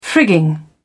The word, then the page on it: frigging: British English